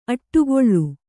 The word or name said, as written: ಅಡಿಗಲ್